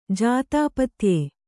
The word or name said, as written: ಜಾತಾಪತ್ಯೆ